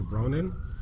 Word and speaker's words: Ronan: He was in Stargate Atlantis, and he plays a character named Dex Ronan, which, oddly enough, rhymes with Conan.